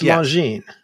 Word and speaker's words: Longines: I'm looking at the Wikipedia. It's a French name. – It's a French name, so it's Longines.